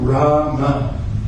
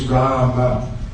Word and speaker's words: Rama: I know Hindi, the development of the Hindi language, Rama is normally pronounced Ram, isn't it? Now, it may not be a very big deal, but the actual word is Rama. is the actual word, Sanskrit word.